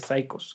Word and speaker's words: Saicos: Y para los que lo quieran buscar, como dije, Demolición, pero los Psychos se escribe S-A-I-C-O-S. Eh, psychos, como se escucha.